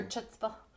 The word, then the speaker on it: chutzpah: Her chutzpah. – Her chutzpah, yes. – Her chutzpah. – So the C is silent? Is that what it is? – It's H.